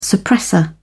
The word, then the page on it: suppressor: British English